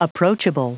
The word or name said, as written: approachable